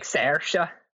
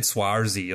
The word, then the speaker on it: Saoirse: Well, I guess you really say it more like Saoirse. – Saoirse Ronan. I think I said Swarzy or something like it at the start, but she's Irish?